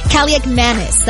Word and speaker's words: Kaliakmanis: Washington losing quarterback Marcus Mariota to a knee injury, being replaced, yes, by Ethan Kaliakmanis. That is correct. That is how you say it.